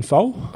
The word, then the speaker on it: Faux: How do you say her last name? – I'm picking it's Catherine Foul. – Foul, F-A-U-X.